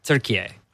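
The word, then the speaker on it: Türkiye: They pronounce it turkey A. – Turkey A. – It's pronounced turkey A.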